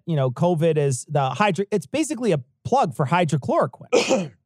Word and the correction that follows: hydroxychloroquine: Or hydro, is it hydroxychloroquine? – I always say it wrong. Hydroxychloroquine.